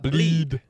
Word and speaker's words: Blidh: How do you say his last name? – Bleed. – Anton Bleed from Sweden. – B-L-I-D-H. – Yes. – Bleed. – Wouldn't it be Bleeda?